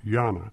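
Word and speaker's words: Jena: Postal Service was renamed Gina after Gina, Germany, which freely translated from German is pronounced Jana.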